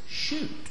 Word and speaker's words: Schutt: It's spelled S-C-H-U-T-T, and I think it's shoot.